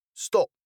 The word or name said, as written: stop